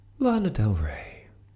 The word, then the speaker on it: Lana Del Rey: Lana Del Rey. I think that's how you pronounce that name, right? – That's how I pronounce it, yeah.